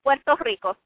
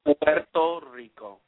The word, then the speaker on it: Puerto Rico: Yeah. It's Puerto Rico. You roll your R's. – Puerto Rico.